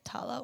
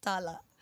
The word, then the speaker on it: Tala: I'm like, should I say Tala or Tala, like the Filo way.